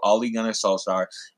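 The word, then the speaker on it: Ole Gunnar Solskjær: Ole Gunnar Solskjaer.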